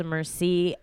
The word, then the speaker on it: Merci: My name is Sasha Merci, and it's spelled M-E-R-C-I, now with a Y, so check yourself.